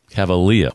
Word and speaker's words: Cavalea: Am I pronouncing that correctly, or is it Cavalea? – Cavalea. – Cavalea.